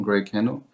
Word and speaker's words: Greycano: Ist es eigentlich Greycano oder Greycano? Habe ich mich letztens gefragt. – Es ist eigentlich Englisch, auch Englisch ausgesprochen, Greycano.